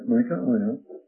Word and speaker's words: Micaiah: So they got Micaiah, and he was not on good terms, incidentally, with Ahab. And did I get his name correctly? Yes, Micaiah, the son of Imlah.